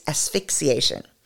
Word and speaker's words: asphyxiation: Autoerotic I always do this, so I'm not even going to correct this error or edit it out because so many of us pronounce asphyxiation poorly. So there it is. I've said it correctly now. It's asphyxiation.